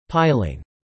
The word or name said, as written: Piling